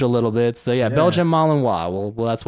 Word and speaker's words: Malinois: Malinois. – How do you spell it? – Malinois. – Okay. So it's French. – R-I-N-O. – So if it is an actual Belgian dog, it's French. So Malinois.